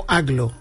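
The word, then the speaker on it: Agloe: No, entiendo que Aglo, yo lo he escuchado pronunciado como Aglo.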